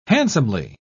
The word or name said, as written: handsomely